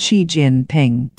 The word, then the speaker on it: Xi Jinping: It's pronounced Xi Jinping.